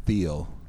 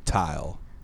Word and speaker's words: Tyle: Jeff Tile or Jeff Thiel? – It's spelled T-Y-L-E, so. – Oh, that's pronounced Tile.